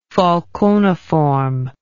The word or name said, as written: falconiform